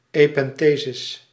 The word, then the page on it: epenthesis: /ɛˈpɛntəzɪs/; Dutch